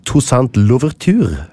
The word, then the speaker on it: Toussaint-Louverture: Og I 1791, under den franske revolusjonen, så benytter da 100 000 slaver, ledet av François-Dominique Toussaint-Louverture.